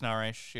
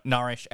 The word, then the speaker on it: Norrish: I'll say my name, you know, Alex Nourish, you know. Sorry, Nourish, Alex Nourish.